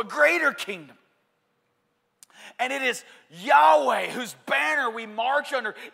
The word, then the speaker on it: Yahweh: There's a lot, and Jehovah's a mispronunciation, it's Yahweh.